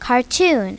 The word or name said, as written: cartoon